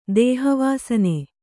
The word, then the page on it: ದೇಹವಾಸನೆ: dēha vāsane